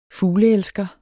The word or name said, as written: fugleelsker